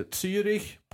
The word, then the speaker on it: Zürich: Rögle mötte Zürich på hemmaplan. – Exemplariskt uttal. – Ja. Fem plus. Det sitter sen tsar. Följer med där till Zürich, ja. Om det nu är så man säger.